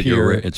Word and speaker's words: Pierre: In Pierre. – Yeah. Mm-hmm. – Notice I pronounced it correctly. – You did, Pierre, South Dakota.